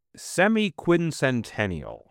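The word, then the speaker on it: semiquincentennial: Well, the word is semi-quincentennial. – Oh, that's right. Not the sesquicentennial.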